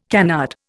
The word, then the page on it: canard: Indian